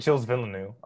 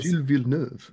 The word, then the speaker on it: Gilles Villeneuve: Okay, sorry, we just got into a whole topic about Circuit Gilles Villeneuve. I didn't even say that right. Anyways, Gilles Villeneuve.